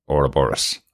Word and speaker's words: Ouroboros: So we're going to pronounce it the way the American show pronounces it as Ouroboros.